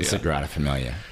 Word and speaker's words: Sagrada Familia: Sagrada Familia. – Sagrada Familia, yeah.